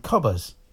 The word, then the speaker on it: Qobuz: and they support a service called Cobbers, and Cobbers Q-O-B-U-Z, that's how you spell it.